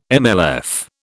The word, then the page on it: MLF: British English